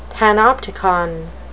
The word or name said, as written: panopticon